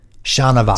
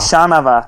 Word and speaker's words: Chanavat: It says, "But the N comes first, not the V." Chanavat. – Chanavat.